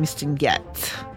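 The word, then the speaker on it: Mistinguett: And I'm wondering if I'm mispronouncing Mistin Get. – That sounds pretty good to me. – It's a double T, but it's missing the E.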